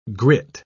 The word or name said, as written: grit